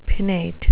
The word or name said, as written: pinnate